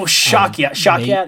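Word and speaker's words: Shawkat: She's such a good actress, and I can never pronounce her name. Aaliyah Shagway. – Oh, Shakya.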